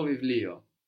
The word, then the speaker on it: βιβλίο: It's written with the β that mm-hmm. looks like a P, but we pronounce a V there, no? Mm-hmm. Βιβλίο.